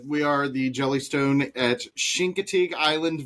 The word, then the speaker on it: Chincoteague: So yeah, the locals, the old school locals pronounce it Chincoteague, and they do it with their teeth clenched. It's the weirdest thing. But yeah, all the rest of us, we call it Chincoteague.